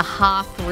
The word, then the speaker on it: Hoth: We're in the Hoth region.